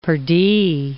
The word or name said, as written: pardi